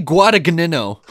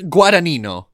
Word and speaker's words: Guadagnino: I'm pronouncing his name properly. – Yeah? – You want me to pronounce it like a Canadian? – No, say it again. – His name is Vinny Guadagnino.